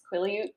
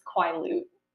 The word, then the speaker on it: Quileute: Because I didn't know how to pronounce Quileute, because I always think it's Quileute.